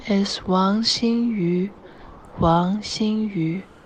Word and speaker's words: Wang Xinyu: Let's listen to her official WTA pronunciation file. Here it is. – My name is Wang Xinyu. Wang Xinyu. – Oh boy, I need to listen to that again, because that's not how I was saying it.